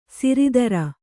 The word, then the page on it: ಸಿರಿದರ: siridara